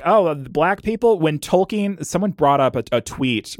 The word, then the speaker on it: Tolkien: Yeah, Tolkien. That's how you say his name.